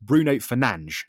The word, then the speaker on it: Bruno Fernandes: His name is actually pronounced, because he's Portuguese, his name is pronounced Bruno Fernandes. That is his name. It's Bruno Fernandes. But the English pronunciation has taken the name and changed it to Bruno Fernandes.